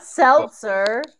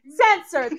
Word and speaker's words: Setzer: Seltzer. Thank you. It's not seltzer like you drink.